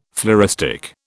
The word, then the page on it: floristic: British English